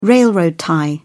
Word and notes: railroad tie: British English

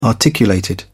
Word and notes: articulated: British English

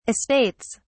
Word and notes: estates: British English

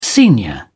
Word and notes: senior: British English

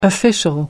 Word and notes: official: American English

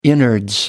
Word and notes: innards: American English

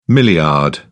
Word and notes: milliard: British English

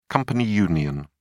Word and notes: company union: British English

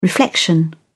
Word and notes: reflection: British English

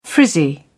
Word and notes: frizzy: British English

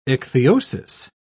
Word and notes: ichthyosis: British English

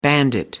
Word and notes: bandit: American English

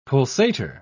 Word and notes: pulsator: British English

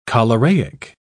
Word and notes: choleraic: British English

